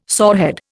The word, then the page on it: sorehead: Indian